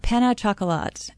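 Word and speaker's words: pain au chocolat: How do you pronounce it? – Penne chocolat. – Penne chocolat. – I've always pronounced it, yeah.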